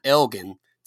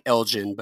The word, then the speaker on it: Elgin: So I grew up right outside of Austin in a little town called Elgin, Texas. Looks like Elgin, but it's pronounced Elgin.